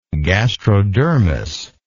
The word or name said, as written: gastrodermal